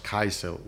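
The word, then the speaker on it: karrysild: Well, one of the things that she gets asked to make is a thing called, which is in Danish is pronounced kajsel, which is curried herrings, which sounds a bit awful, but is amazing.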